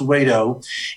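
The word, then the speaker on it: Wedow: It's W-E-D-O-W, but pronounced Weddo.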